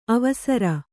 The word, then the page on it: ಅವಸರ: avasara; Kannada